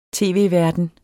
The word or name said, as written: tv-verden